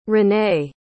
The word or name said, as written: ranae